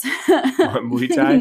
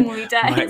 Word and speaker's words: Muay Thai: Jiu-jitsu and, Mai Tais. – Muay Thai. Muay Thai.